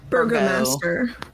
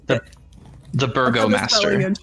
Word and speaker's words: Burgomaster: How is it actually pronounced? Because Three would pronounce it correctly. – I think it's Burgo. – Burgomaster. – Okay. The Burgomaster. There's another thing.